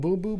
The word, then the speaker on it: Bumbu: I'm going Bumbu because it's Bumbu. Closer to Boom Boom. – Okay. – So I love Boom Boomer. – Because I think Bumbu brings the Boom Boom.